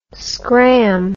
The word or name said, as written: scramb